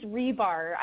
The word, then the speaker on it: Ribar: It's Ribar.